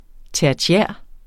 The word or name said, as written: tertiær